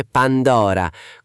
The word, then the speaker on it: Pandora: «Ehu tempus fugit, sed vas persistit!» Si dice Pandora, con l'accento sulla O aperta, ignoramus.